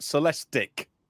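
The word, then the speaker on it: Celestiq: The Cadillac Celestiques. How do you say it again? – I was told Celestic.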